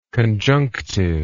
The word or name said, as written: conjunctively